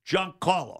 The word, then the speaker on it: Giancarlo: Giancarlo, because for the guy who got ticked off because I said, you got to get, oh, you're not saying his name right. Giancarlo.